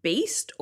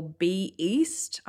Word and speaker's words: B-East: I've always called it the B-East, but I think it often gets called the Beast.